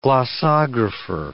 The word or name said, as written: glossographer